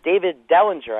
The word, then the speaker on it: David Dellinger: And David Dellinger, I believe his name is. – Yeah, it is. – Did I get his name right? – You did.